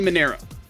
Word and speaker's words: Manero: Big Jay, in which film did John Travolta play the character Tony Moreno? – Tony Moreno. – Actually, I said that wrong. Tony Manero.